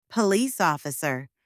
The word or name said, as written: police officer